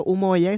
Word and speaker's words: Umoja: así que para recapitular, tenemos Spicy Nipples, que lo pueden encontrar en las redes sociales, colectivo Humoya, y se escribe Humoja. UMOJA y también con el verbo en la piel.